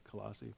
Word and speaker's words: Colossae: And the city of Colossae, I don't know if I'm saying that right, it's Colossae. – Close enough.